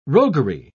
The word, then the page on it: roguery: British English